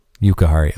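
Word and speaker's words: Jujhar Khaira: Juki Haru.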